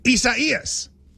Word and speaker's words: Isaias: I-S-A-I-A-S. – And it's four syllables. I do know that. I-S-A-I-A-S. And it's like... – I-S-A-I-A-S.